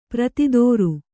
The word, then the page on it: ಪ್ರತಿದೋರು: pratidōru